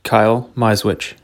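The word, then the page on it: Kyle Misewich: English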